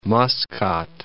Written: Masqat

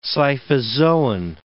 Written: scyphozoan

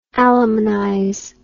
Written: alimonize

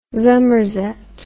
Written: zummerzet

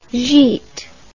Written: gite

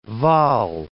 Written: Waal

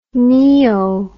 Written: neo

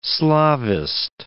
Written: Slavist

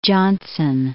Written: Jonson

